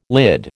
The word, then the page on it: lid: American English